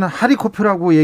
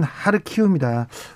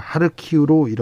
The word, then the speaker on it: 하르키우: 러시아의 어, 러시아 사람들은 하리코프라고 얘기하는데 우크라이나 발음은 하르키우입니다. 우리는 어, 우크라이나식 지명을 사용하기로 했습니다. 그래서 저희 다 저희는 어, 지금까지는 하리코프라고 얘기하는 언론이 많았는데 어, KBS에서는 주진우 라이브에서는 우크라이나 발음으로 하르키우로 이렇게 어, 정정해서 얘기 드리겠습니다.